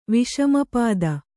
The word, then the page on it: ವಿಷಮಪಾದ: Kannada